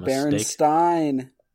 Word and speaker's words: Berenstain: Or is That's it Berenstain? – Berenstain. I'm laying that down right now. There's no other way to pronounce it.